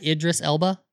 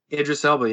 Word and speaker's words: Idris Elba: Did I say it right? Idris Elba? – Idris Elba, yeah.